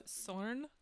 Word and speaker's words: Sorn: My name is Sorn. – My name's Shim. – My name is Thorn in your side. – Oh, very similar rhymes with mine. – Yes, it does. – Sorn, mine's Sorn Europide is my full name.